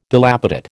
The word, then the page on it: dilapidate: American English